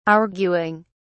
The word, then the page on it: arguing: British English